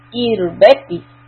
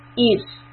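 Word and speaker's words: إربة: Irbati, the ra has a sukun. When the ra has sukun, we need to look at the sign that came before. If there's a kasra, then the ra will be pronounced softly.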